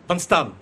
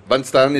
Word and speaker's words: Van Staan: Et vous avez écrit avec votre complice Marc Van Steen, je prononce bien Stan ? – Van Staan. – Van Staan, excusez-moi, petite friandise du cyclisme belge.